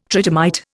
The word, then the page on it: tridymite: Indian